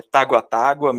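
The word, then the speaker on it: Tagua Tagua: Eu até estou tentando ver como é que se pronuncia, se é Taguatágua, Taguatágua, enfim. É Taguatágua mesmo, que é do cantor, compositor e produtor Felipe Puperi, que já fez parte da banda, acho que é Wanna Be Djalva.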